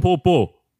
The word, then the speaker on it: Tampopo: That's the proper pronunciation. – Tampopo.